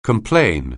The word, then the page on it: complain: anglais